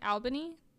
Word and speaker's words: Albany: So, after graduating from high school, he became a pre-med student at the State University of New York's, I'm not sure how to say this, it's like Albany? A-L-B-A-N-Y? Albany? – Yeah. – That sounds right. – Albany, New York.